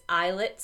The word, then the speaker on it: islet: islet cell transplant. Islet, for everyone out there, is spelled I-S-L-E-T, like the tropical island, not E-Y-E, like the fabric.